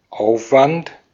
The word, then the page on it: effort: /ˈɛf.ɚt/; [ˈɛf.ɚt]; /ˈɛf.ət/